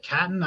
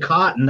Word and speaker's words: Catton: I'm not sure if I pronounced that right, but go ahead and take it away. – Yeah, you got it. I actually pronounced his name because I play a lot of Chell. Every time his name popped up, I pronounced it wrong. – I almost said Cotton. That's why I kind of stumbled. – I pronounced it Catton. I pronounced it Catton for a long time.